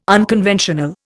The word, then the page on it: unconventional: Indian